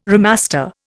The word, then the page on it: remaster: Indian